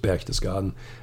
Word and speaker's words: Berchtesgaden: Pronouncing it in English wouldn't make any sense. The name is just Berchtesgaden.